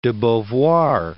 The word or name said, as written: Beauvoir, de